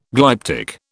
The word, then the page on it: glyptic: British English